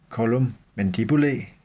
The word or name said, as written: collum mandibulae